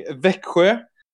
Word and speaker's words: Växjö: Okay, Daniel is representing Växjö.